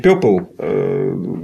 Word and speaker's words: Purple: Это же закрытый слог, оно читается. No, Purple. Purple. Ну, как Burn.